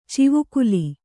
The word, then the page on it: ಚಿವುಕುಲಿ: civukuḷi